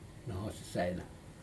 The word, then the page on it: nohos saina: Aneityum